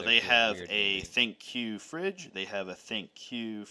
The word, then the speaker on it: ThinQ: Well, okay, so it's spelled thin with and then a capital Q, but they say you're supposed to say it ThinQ.